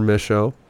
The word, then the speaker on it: Mischo: My Instagram is Trevor Mischo. My last name is spelled M-I-S-C-H-O for all y'all that don't know.